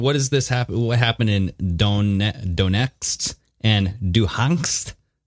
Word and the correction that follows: Luhansk: Luhansk, yeah.